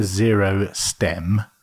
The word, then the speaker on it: Zero Stem: It's called a Zero Stem. But apparently it looks like Zerostom, which is some kind of cream or something, I'd imagine.